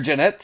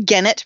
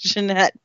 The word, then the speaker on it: Gennett: Jeanette, is that how you pronounce it? – Yep, Scooter Jeanette. – I was going to say Gennett for some reason, even though I know it's wrong. It's Jeanette, but, like, I want to pronounce it incorrectly, apparently.